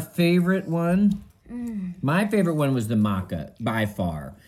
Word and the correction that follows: matcha: The matcha? – How do you say it?